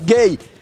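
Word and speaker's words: Gueye: Gay. – Ah, su nombre. – Genera un poco de confusión porque se escribe Güeji. – Ah. – Pero ya explicó él que la pronunciación es gay.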